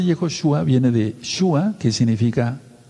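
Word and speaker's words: Yehoshua (יהושע): se pronuncia Yehoshua o Yahoshua.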